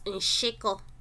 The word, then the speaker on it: nshiko: The last double consonant, Udamkpi, we have is the SH. And it's pronounced she. An example is nshiko.